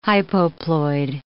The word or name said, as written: hypoploid